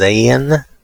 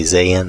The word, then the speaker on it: Zayin: Now we're looking at the next stanza, and I'm going to pronounce this as Zayin. It's verses 49 through 56, and when I say Zayin, that's supposed to represent the Hebrew letter Z-A-Y-I-N.